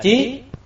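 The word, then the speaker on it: trí (識): Chữ thức này nên đọc là trí.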